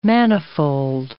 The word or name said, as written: manifold